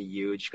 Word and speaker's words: Euge: Instead of Eugene, people call me Huge because it sounds like huge.